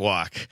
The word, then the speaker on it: Waukesha: And I like to say in Waukesha, woke is spelled WAUK, walk radio. And we pronounce it walk.